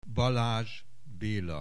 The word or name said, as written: Balazs Bela